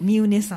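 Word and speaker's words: 三峰: ですが、多分 4 番目に高い山がこの三宗、ね、三宗になるんですね。これは百名山には入ってないんですね。 – 百名山じゃないですけど 200 名山ですね。 – 200 名山に入っている山ということなんですね、はい、であの実は昨日奈良の三宗山というのを登ってきました、それが 3 つの峰と書いて三宗と読むんですけど、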